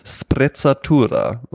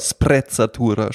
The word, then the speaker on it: Sprezzatura: Und der Begriff ist ja Sprezzatura, ja? mit zwei Z, ein italienisches Wort. Ja? Und, ähm, und einem spitzen S. – Äh, genau, Sprezzatura spricht man es aus, ja.